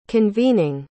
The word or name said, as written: convening